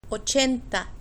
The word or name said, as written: ochenta